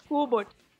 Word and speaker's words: Kubot: Ah, é importante a pronúncia, gente. Lucas Kubot. – Obrigado. – Não é Kubot, Kubot é outro.